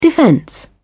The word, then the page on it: defense: British English